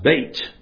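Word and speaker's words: bait (ב): Because that Hebrew letter that you see there, pronounced bait, is actually in the original.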